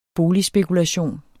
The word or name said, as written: boligspekulation